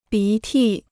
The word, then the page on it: 鼻涕: bí tì